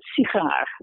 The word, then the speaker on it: sigaar: Now you take a word like cigar in English. Now the first syllable is going to be reduced. It's cigar, right? And you have exactly the same word in Dutch, but it's sigaar, right? So the first syllable is not reduced. It's secondary stress.